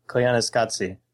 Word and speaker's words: Koyaanisqatsi: I think that's the name of the movie, is Koi Skanti? – I don't know. – Sean Byron and I were talking about it a while ago, and... I was mispronouncing it. It's Koyaanisqatsi.